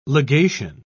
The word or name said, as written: legation